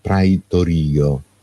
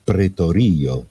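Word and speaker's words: pretorio (πραιτωρίῳ): In greco, così come lo leggiamo noi, secondo la pronuncia erasmiana, cioè la pronuncia di Erasmo da Rotterdam, leggiamo praetorio. Secondo la pronuncia invece di Hans Reuchlin, studioso tedesco, precedente Erasmo da Rotterdam, qui bisognerebbe leggere pretorio, perché il gruppo alfa iota, il dittongo, si leggerebbe e.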